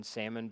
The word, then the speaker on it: salmon: And I remember when the pandemic started, somebody said, I saw a meme that said we might as well start pronouncing the L in salmon because it just doesn't, nothing matters anymore.